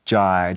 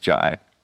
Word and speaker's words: Jai: No, it's Jai, just like sky. No, yeah. Like Jay? Okay, yeah, Jay. So a lot of people call me Jay, but... – you just let it happen. – Yeah, if it rhymes with sky or pie, yeah.